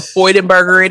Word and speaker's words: Freudenberger: So the term burnout was coined in the 1970s by the American psychologist Herbert Freudenberger. I'm hoping I'm saying that right, but you know you're doing better than I would. So girl, Freudenberger it is.